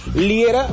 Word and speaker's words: Liera: Fernando Liera. Fernando Liera, se escribe mi apellido L-I-E-R-A.